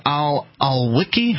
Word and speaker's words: al-Awlaki: As we learned earlier, Mark's story that he brought in explains that in the Anwar al-Awlaki, how do you pronounce it? – I'm not even going to try. – Al-Awlaki. Al-Awlaki and his 16-year-old son and the other gentleman who was bombed to death by the United States.